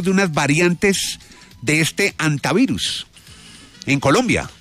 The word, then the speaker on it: hantavirus: Pues antavirus, se supone que es, pero hay mucha gente que le dice también hantavirus, ¿no? Entonces, sí. Yo pensaría que es antavirus porque pues el nombre está en español y como todo el mundo sabe en español la H pues no se pronuncia, ¿no?